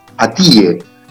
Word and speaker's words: Atiye: Se escribe Atiye. A-T-I latina, Y-E. Atiye es, es como se pronuncia en turco.